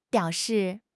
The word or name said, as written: biao3 shi4